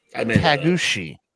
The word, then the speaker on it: Taguchi: The other one was Ryushi, R-Y-U-S-U-K-E. – Rashikushi. – Tagushi. T-A-G-U-C-H-I.